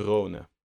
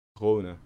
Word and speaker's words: Rhône: Het zit zo'n dakje op, dus hoe spreek je een dakje met een, een O met een dakje uit? Maar oh, Rhône.